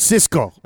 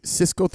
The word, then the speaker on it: Sisqo: But I think it is Cisco 305. It's spelled Sisqo 305.